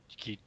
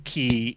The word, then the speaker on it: chi: they pronounce it key, even though that's like not the per- correct pronunciation of the Greek letter at all.